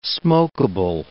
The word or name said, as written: smokeable